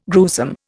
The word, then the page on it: gruesome: Indian